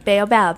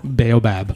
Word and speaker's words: baobab: The baobab. – Baobab, yeah. – Which is a crazy, crazy looking tree. – Really crazy. – Yeah. – And we drank a lot baobab juice.